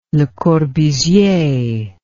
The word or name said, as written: Le Corbusier